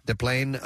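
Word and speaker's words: Des Plaines: And the now third largest Mega Millions jackpot ended with a winner in Deplane. – Deplane. – 20 miles. Is that how you pronounce it? – Yeah. – Deplane, Illinois? – Yes, my friend.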